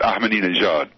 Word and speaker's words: Ahmadinejad: this H is a soft H, but the important point, just for your listeners who are wondering, the accent is on the last syllable of his name, and this is an unusual pattern for American, for English speakers. So it's Ahmadinejad.